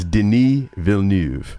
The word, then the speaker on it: Villeneuve: Are we pronouncing the name right? – Yes, it is Denis Villeneuve.